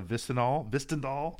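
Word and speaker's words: Hvistendahl: The author is Mara Vistendahl. Oh, I almost got it right twice. – Close enough. – Yes. Close enough. The H is silent.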